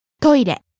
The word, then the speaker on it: トイレ: Next, トイレ。 – Toilet, restroom, bathroom, lavatory. – ト、イ、レ、トイレ。 – Starting high, falling on the second syllable, い、staying low. – トイレ。